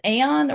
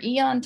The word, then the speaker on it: Aeon: I guess it's pronounced Aeon or Eon timeline, A E O N.